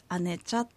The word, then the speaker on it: 姉茶: はい、姉茶というのは日本語でもちょっと難しくて住所を言うときは、聞き返されたりするんですけど、お姉さんの姉にお茶の茶と書いて姉茶と言います。